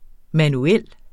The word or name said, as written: manuel